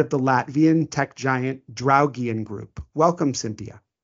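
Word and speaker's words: Draugiem: And I really liked your pronunciation of the Latvian word, Draugian Group. I know that for an American, the R's are quite difficult, and I loved it.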